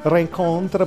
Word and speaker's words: Rencontre: Lelio lo pronuncia Rencontre, per cui che sarebbe Reincontri,